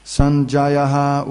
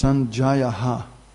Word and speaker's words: Sanjayaha: Sanjayaha uvacha. No, it's Sanjayaha. Because it's got a dot.